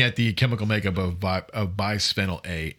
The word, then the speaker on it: Bisphenol A: I know I didn't say that right. Bisphenol? I don't know how to say it. – Bisphenol A. – There you go. – Okay. Bisphenol A is 3 atoms of hydrogen, 1 atom of carbon.